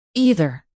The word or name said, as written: either